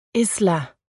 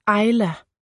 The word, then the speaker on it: Isla: De fleste kalder mig Isla, men det udtales Ayla. Ligesom det engelske ord for ø, island, bare uden nd.